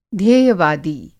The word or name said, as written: ध्येयवादी